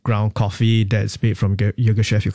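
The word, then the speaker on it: Yirgacheffe: But is that how you could pronounce it? Say that again. – Ethiopia Yogeshef. – Yogeshef. – That's right.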